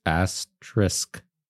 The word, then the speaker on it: asterisk: Asterisk. – Sk. Sk. Not X. – Not X. – Okay. – It's A-S-T-R-I-S-K. T-E-R. – Asterix. – I-S-K.